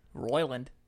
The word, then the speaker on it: Roiland: Co-creator Justin Roiland. Am I saying that right? – Roiland.